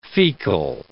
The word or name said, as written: fecal